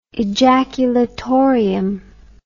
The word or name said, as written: ejaculatorium